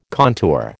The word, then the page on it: contour: American English